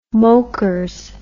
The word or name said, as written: mokers